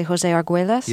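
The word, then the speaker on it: José Argüelles: And this is by, I hope I'm pronouncing his name correctly, José Arguelles. – Yes.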